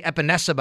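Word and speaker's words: Epenesa: A.J. Epinesa, by the way, is how you say his name.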